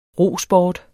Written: rosport

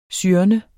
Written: syrne

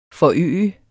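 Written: FORØGEDES

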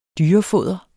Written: dyrefoder

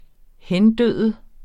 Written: hendø

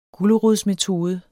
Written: gulerodsmetode

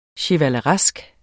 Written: chevaleresk

